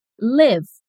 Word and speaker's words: live: O nosso primeiro caso é um dos mais famosos, o que a gente escreve L-I-V-E. Quando a gente pronuncia como Live. O significado é viver ou morar. Repete. Live. – Então eu posso dizer: Eu moro no Canadá. I live in Canada. Repeat. I live in Canada. Já a outra pronúncia com essa mesma escrita é live.